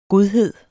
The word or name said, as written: godhed